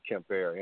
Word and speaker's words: Quimper: And the team was called Quimper, pronounced Kemper.